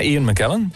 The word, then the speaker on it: Ian McKellen: En gespeeld een nog altijd briljante Ian McKellen. Zeg ik dat goed? – Ja, Ian McKellen.